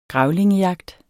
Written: grævlingejagt

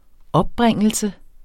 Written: opbringelse

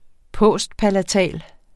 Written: postpalatal

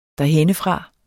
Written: derhennefra

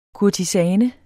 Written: kurtisane

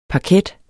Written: parket

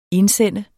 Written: indsende

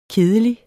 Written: kedelig

